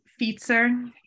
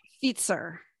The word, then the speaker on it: fietser: Oh, fietser. – Fietser.